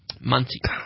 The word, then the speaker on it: Muncie: Muncie. – Is that not how you say it? – No, that's how you say it.